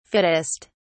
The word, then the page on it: fittest: British English